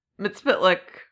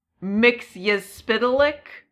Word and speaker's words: Mxyzptlk: And then I heard it said Mxyzptlk by a few people. But I've also a few, like, shows have said it, I think, Mxyzptlk. So, like, basically the same, but with, like, pronouncing more of the letters.